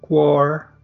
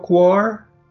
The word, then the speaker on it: Quaoar: QUAR. Q-U-A-O-A-R. Son demasiadas vocales pegaditas y la pronunciación, pues yo le digo quar, ¿tú cómo le dirías? Igual quar,